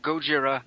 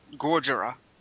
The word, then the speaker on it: Gojira: It's pronounced Gojira, not Gorgira.